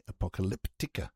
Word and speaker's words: Apocalyptica: And next we head off to Finland, Helsinki, for the band Apocalypta. Hang on, get it right. Apocalyptica.